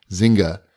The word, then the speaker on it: Zynga: Zynga. How do you say that again? – Zynga.